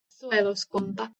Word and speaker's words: Suojeluskunta: The Finnish national militia is called the Sujelskunta. Let's see how Forvo pronounces that. – Suojeluskunta.